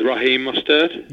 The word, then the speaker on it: Raheem Mostert: Is Raheem Mostert? – Yeah, yeah.